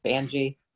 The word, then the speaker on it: Vange: It's actually Angie with a V. So just Vange.